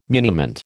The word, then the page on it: muniment: American English